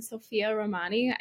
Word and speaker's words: Sofia Romani: Well, I'm Sophia Romani.